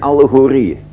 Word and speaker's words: allegorieën: En Jezus spreekt over het koninkrijk der hemelen in beelden en in allegorieën. In beelden en in allegorieën. Ik zal dadelijk zeggen wat allegorie is. Allegoria, allegorieën. A-L-L-E-G-O-R-I-E-E-N. I-E-E-N. Klemtoon op de laatste letter, even verdubbeld die.